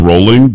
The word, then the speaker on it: Rowling: Having said that, a lot of people think it's J.K. Rowling. It's not. It's Rowling because she says Rowling, but I'm with you. – But it looks spelled like Rowling, right? Yeah, it's spelled R-O-W as a row.